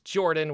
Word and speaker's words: Jorphdan: My name is Jorphdan with a silent PH in the middle, and I'm joined, as always, by my wonderful co-host Sir Lucien over at Sir Lucien Gaming.